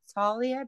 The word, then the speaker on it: Talia: But we had we've got a guest on tonight, Mother Superior Talia. Did I say that right? – Yes.